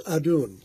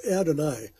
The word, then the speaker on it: Adon: Today's word is Adon, sometimes pronounced Adonai.